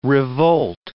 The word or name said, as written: revolt